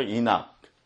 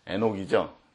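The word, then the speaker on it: Enoch: 이낙, 에녹이죠? 지금 영어 발음이 나와요.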